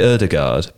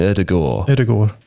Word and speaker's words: Ødegaard: You don't have the D in there at all. – Martin Ødegaard, if you're actually like Norwegian. That's so I would say Ødegaard, but that's wrong. Ødegaard. – You sort of soften the D.